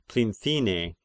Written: Plinthine

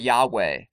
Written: Yahweh

